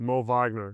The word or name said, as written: Moritz Wagner